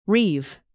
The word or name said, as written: reave